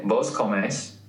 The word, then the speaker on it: vos comes: The stress goes on the last syllable. So if you were going to say, like, tú comes, comes. Instead, you say, vos comes.